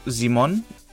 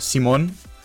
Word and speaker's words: Simon: Die Story fängt eigentlich ganz simpel an mit der Schicht, äh, der Erzählung darüber, dass, naja, Bora zum Bohren da sind und Simon, oder Simon, je nach Betonung, Simon.